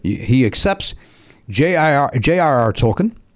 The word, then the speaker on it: Tolkien: The answer is J.R.R. Tolkien or Tolkien. – Those are pronunciations. – Oh, so he's going alternate pronunciations. – Some people pronounce it Tolkien. – Either answer is okay.